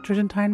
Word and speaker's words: Tridentine: And if you stay tuned, you can hear what he told me about the Tridentine Rite. – Tridentine, is that how you pronounce it? The Tridentine Mass? – Sure.